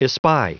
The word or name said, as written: espy